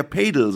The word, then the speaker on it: Padel: Alle Leute, die es cool aussprechen wollen, sagen Paddel. – Ja? – Aber selbst wenn es ein englisches Wort wäre und man würde es so schreiben, würde man ja Padel sagen und nicht Paddel. – Ey, das heißt Padel? – Ich glaube ja. Also ich glaube, wenn man es korrekt ist, sagen alle Paddel. Aber es ist falsch.